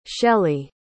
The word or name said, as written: shelley